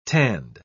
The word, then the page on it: tanned: British English